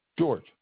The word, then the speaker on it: George: George. – J-E-O-R-G-E? – No, G-E-O-R-G-E.